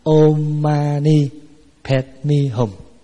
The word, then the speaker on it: Om Mani Padme Hum: Là Án Ma Ni Bát Di Hồng, mà đọc theo tiếng Phạn là Om Mani Padme Hum.